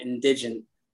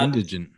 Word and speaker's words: indigent: There are plenty of programs, plenty of departments and organizations that have attorneys to help people out who are quote unquote indigent. I think I pronounced that wrong. But indigent.